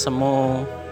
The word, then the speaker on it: SMU: ada cinta SMU atau dulu bacanya cinta semu dan lain sebagainya ya itu sinetron-sinetron di tahun 2000 eh tahun 90-an sama 2000-an ya.